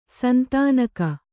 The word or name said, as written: ಸಂತಾನಿಕೆ